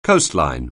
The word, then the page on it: coastline: anglais